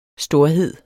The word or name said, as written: storhed